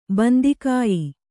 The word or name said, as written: ಬಂದಿಕಾಯಿ